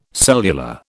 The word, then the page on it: cellular: British English